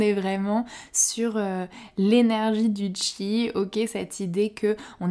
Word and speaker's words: qi: À présent, je vais passer à notre deuxième trésor, le chi. Ça s'écrit qui hein, q-i, mais ça se prononce donc chi.